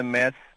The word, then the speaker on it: Metz: Podíamos ir a Metz, como se diz com a pronúncia portuguesa.